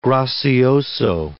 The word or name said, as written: gracioso